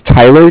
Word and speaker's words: Tyler: My name is Tyler, T-Y-L-E-R. – Okay. Yeah. – And I'm calling with Cardiff. It's spelled C-A-R-D-I-F-F as in Frank.